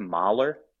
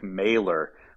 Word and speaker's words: Mahler: I think we said Rick Mahler. We pronounced him like his distant cousin, Gustav. But friend of the show, Mark Simon, let us know that it's Rick Mailer, more like his other cousin, Norman Mailer.